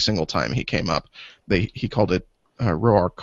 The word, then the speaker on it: Roark: Yeah, I think it is pronounced Roark, which I learned this week.